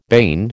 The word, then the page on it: bane: American English